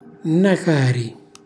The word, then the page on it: nnacari: calabrese